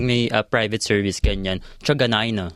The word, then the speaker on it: Truganina: Ako, nung isang beses kasi, di ba dati na kapag trabaho ako, sabi ko, uh, Truganina.